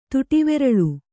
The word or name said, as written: ತುಟಿವೆರಳು